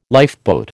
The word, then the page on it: lifeboat: American English